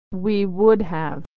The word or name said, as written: we would have